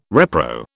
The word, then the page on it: repro: British English